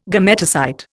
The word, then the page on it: gametocyte: Indian